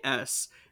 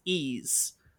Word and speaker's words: Ys: It's Ys. If you've ever seen that game and you have no idea how to pronounce it, it's Ys. That's it.